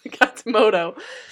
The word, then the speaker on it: Katsumoto: I knew I said it wrong the last time you gave me a look. Katsumoto.